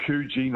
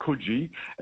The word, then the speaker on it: Coogee: And over there, there's a place called Coogee, not Coogee.